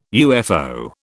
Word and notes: UFO: British English

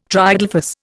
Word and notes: triadelphous: Indian

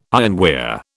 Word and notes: ironware: British English

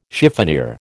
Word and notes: chiffonier: American English

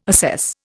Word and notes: assess: Indian

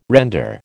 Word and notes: render: American English

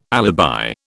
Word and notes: alibi: British English

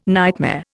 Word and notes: nightmare: Indian

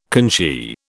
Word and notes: conchy: British English

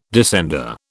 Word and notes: descender: British English

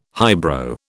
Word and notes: highbrow: British English

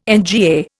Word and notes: NGA: Indian

